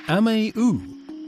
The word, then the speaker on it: Amewu: Let's linger in Berlin for Ameu, which is spelt A-M-E-W-U, but you don't pronounce the W.